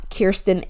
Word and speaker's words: Kiersten: If you want to follow me on TikTok, where I post more often, that is dot Kiersten H.